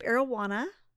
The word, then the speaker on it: Arowana: I went to Camp Arowana, which is rhymes with camp marijuana.